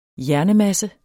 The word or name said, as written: hjernemasse